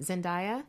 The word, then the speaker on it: Zendaya: and getting to hang out with uh, how do you say it, Zendaya? – Yes, getting to hang out with her.